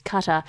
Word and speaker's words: Qatar: in the shameful past. Sorry, it's pronounced Qatar.